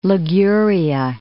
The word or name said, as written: Liguria